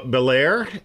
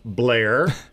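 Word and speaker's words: Bel Air: We're going to Bel-Air, or as they pronounce it there, I believe, Blair.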